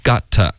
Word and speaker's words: got: Got, 不是 get. Okay, got, 嘴巴要张开 got.